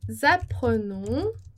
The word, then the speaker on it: apprenons: Em seguida, nous apprenons vite. Nós aprendemos rápido. Nous apprenons. Nós aprendemos, nous apprenons. Então, esse E não vai pronunciar, né? Nous apprenons. E a gente vai ter também a liaison.